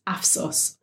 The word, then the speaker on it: AFSOS: He released his stand-up special, Take It Easy, on Prime Video to critical acclaim and has his own dark comedy series, AFSOS, also on Prime. Am I saying that right? – Yeah, you're absolutely right.